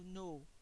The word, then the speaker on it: know: Le verbe savoir, to know, là on ne prononce ni le K ni le W.